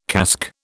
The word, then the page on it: casque: British English